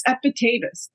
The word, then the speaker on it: Epictetus: So who is Epictetus? Tell us a little bit. I don't even know how to say it. – Yeah, no, you got it. You nailed it in your beautiful Swedish accent.